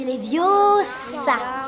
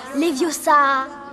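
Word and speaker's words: Leviosa: C'est Léviosa. Et pas Léviosa.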